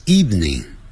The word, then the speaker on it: evening: Tomorrow 也是这样的明天 T-O-M-O-R-R-O-W 你明天想要做什么 What would you like to do tomorrow 你今天晚上想要做什么 What would you like to do this afternoon this evening evening 就是晚上 E-V-E-N-I-N-G 下午是 afternoon evening 这个呢我们很容易读错哦 E-V-E-N-I-N-G 我们可能会读成三个音节 Evening 可是老外是两个音节 Evening Evening Evening Evening、啊、就讲 Evening 老外不会念成 Evening、